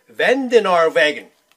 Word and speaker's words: Vandenaardweg: And we thank you, Peter Vandenaardweg of Patterson as well. – Properly pronounced. A little rock from a dirt path.